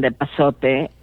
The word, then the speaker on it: epazote: hoja santa, depends on, on the region, but uh, hoja de aguacate, av- wild avocado leaf, or but mainly cilantro and epazote are the most common. – Yes. Okay. So I want to pronounce it right. Epazote. That's E P A Z O T E.